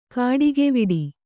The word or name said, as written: ಕಾಡಿಗೆವಿಡಿ